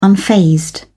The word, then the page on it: unfazed: British English